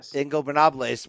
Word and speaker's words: Ingobernables: Twitter pic of her and the Los Ignobernables. Man, I always butcher that. – Ingobernables, which is obviously a shout out to Andrade, her real life boyfriend, apparently right now, who started that faction in Mexico.